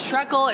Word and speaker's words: Trekell: Hey, it's Trekkle. It's Trekkle, Trekkle, whatever you want it to be. So I was trained to say Trekkle.